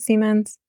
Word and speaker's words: Siemens: And to go back to Siemens, is that how you pronounce it, Siemens? – Okay. Yeah, that's right.